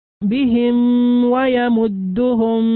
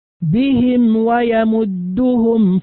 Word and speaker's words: بهم ويمدهم: البعض ينطق بها خطأ فيقول بهم، بهم ويمدهم في طغيانهم هكذا، وهذا النطق خطأ. النطق الصحيح بهم ويمدهم في طغيانهم.